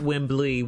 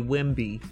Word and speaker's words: Wemby: Just call him Wemby, not Wembley. Wemby. That's it.